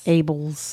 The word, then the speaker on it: Abels: Michael Abels. Abels. – Okay, how do you spell it? – A-B-E-L-S. – Abels.